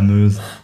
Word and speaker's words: Meuse: Das ist wieder mein schlechtes Französisch und die Betonung. Ne? – Ja, genau. Ich glaube, es heißt wirklich La Meuse.